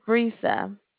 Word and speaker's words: Brisa: My name is Brisa. – How do you spell that? – B-R-I-S-A.